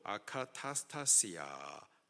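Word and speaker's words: 아카타스타시아 (ἀκαταστασία): 혼란, 헬라식 발음으로 아카타스타시아, 한국말로 다시 번역을 하면은 혼란, 요란, 소동, 무질서함, 무법함, 무정부한 상태를 뜻합니다.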